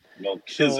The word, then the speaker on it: Melchizedek: Ancient priest, Melchizedek, something like that, right? – Melchizedek.